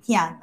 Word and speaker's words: Pia: So first of all, my name is Geraldine Pia. So if you have problems pronouncing my last name, it's actually Pia, like, you know, popia, right?